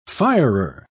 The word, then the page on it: firer: British English